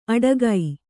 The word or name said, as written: ಅಡಗೈ